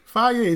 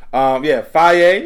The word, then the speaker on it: Fage: Faye is spelled F-A-G-E. – Yeah, I almost said it wrong, but I was like, let me get that Faye.